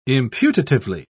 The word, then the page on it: imputatively: British English